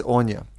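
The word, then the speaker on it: Áine: So her name is Anya, and it's spelt A-I-N-E.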